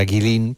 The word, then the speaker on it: Guilin: Bueno, esta ciudad de Guilin, por si alguien la busca en internet, se escribe G-U-I-L-I-N, como si fuera Guilin, pero se pronuncia Guilin.